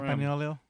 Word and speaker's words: Campagnolo: Campagnolo. – SRAM for me. – Campagnolo. – Campanola. – Something like that. – Now you said it wrong. So now I can't say it right.